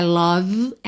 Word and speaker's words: love: Vamos aproveitar que é dia dos namorados, que nós estamos falando de amor, para caprichar na pronúncia dessa palavra, que não é love, não é love, é love. Love. L-O-V-E. Bom, o E não é pronunciado. E o O não é um O caprichado, gordinho, redondinho.